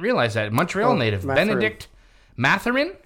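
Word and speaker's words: Bennedict: Benedict. Don't pronounce the T. – Benedict.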